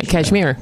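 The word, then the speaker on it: Kashmir: Yeah, Cashmere. Yeah, yeah, that's how you say it.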